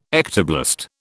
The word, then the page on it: ectoblast: British English